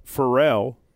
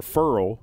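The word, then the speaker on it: Ferrell: Cleland Farrell, or Furl is how you actually say it. – I wish you had gotten to the commissioner. – What's that? – To say his name right. – Yeah. Todd McShay taught us that at the combine. He said, you know it's Furl. I'm like, no, it looks like Farrell to me, but it's Furl.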